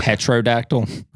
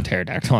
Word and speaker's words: pterodactyl: Petrodactyl? – Or whatever. Yeah, it's called No, it's a pterodactyl. I'm just fucking with you. Spell with a P. – Yeah. It is spelled with a P. – Mm-hmm. P-T-E-R-O.